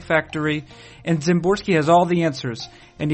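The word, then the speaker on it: Szymborski: The progenitor of the Zips projection system, and the person for whom we might call Zips an eponymous projection system, is Dan Zimborski. The S is silent, you might say.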